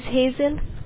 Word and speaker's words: Hazel: My name is Hazel.